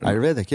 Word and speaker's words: Ayurvedic: Ayurvedic. I never say that correctly, but Ayurvedic, yeah.